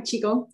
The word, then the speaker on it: Qigong: Is a Pilates instructor and is training or is now qualified as a Qigong instructor. I don't even know if I've said it right. Mm-hmm. – Yeah, you're right. Qigong, yeah.